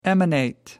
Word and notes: emanate: American English

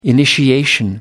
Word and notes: initiation: American English

